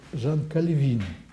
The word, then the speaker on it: Кальвин: И поскольку он французского происхождения, то его фамилию правильнее произносить с ударением на последнем слоге, поскольку в французском языке большинство слов произносится с ударением на последнем слоге. Так что вот Жан Кальвин. Но когда говорят Жан Кальвин, это не совсем точно, но, так сказать, какой-то большой ошибки в этом тоже нет.